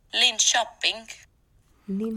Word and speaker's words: Linköping: Linköping.